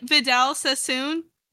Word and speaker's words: Vidal Sassoon: Vidal Sassoon? – Yeah.